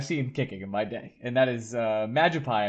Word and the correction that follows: Mangiapane: I believe is how you say his name. Magic? He sounds like a Pokemon. – Mangiapane. You're not even in the room, like in the fucking stratosphere with that.